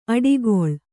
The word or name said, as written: ಅಟ್ಟುಗೂಳು